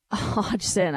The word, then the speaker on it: Hodgson: LA gets Hayden Hodgkinson and Kevin Connauton. I apologize. That's Hayden Hodgson. I really butchered that, everybody.